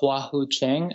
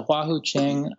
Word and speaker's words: Hua Hu Ching: The one that I've loved for a long time is the Hua Hu Ching. And I told you about this one. It's the Hua Hu Ching.